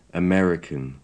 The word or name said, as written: american